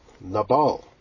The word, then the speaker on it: Nabal: Nabal. Nabal, as Anglo-Saxons would like to pronounce it. N-A-B-A-L. And some of those verses that talk about the fool are clearly referring to Nabal.